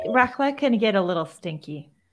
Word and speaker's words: raclette: which is how do you say that cheese? Warm raclette? – Raclette? I'm not sure. – Raclette Swiss cheese. Raclette, thank you.